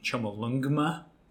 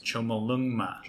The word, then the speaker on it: Chomolungma: Do you think how much do you pronounce the G? Chomolungma. – I think you're saying much? It. I think that is 100% correct. – Chomolungma.